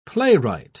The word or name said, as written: playwright